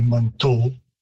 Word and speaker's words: Manitou: I myself and the speakers that I'm most familiar with would say Manitou with an O rather than U,